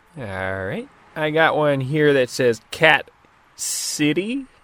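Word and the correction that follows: Cat Sìth: Am I pronouncing that correct? Cat City? – That ain't a city I ever want to visit. – Cat She. And sure, this is the Irish variation.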